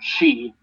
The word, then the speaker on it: Xi: Wait, why is that Zhu if the leader of China's name is pronounced Xi and spelled XI.